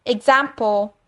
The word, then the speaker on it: example: 大家要注意的地方就是，for example，它的 example 例句，它的发音 example。有没有发现我的 P 不是发 example，for example，这样子是不对的。